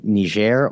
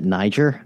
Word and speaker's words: Niger: We've talked a lot before on this program about what's going on in the West African country of Niger, or depending on how you pronounce it, Niger, this country that's just below the Sahara Desert in this region we call the Sahel.